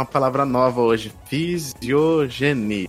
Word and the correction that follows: filogenia: Filogenia. Ih, falei errado já, filogenia.